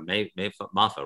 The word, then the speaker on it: MAFA: M-A-F-A. I don't know if I'm pronouncing that right. MAFA or MAFA, right? – MAFA.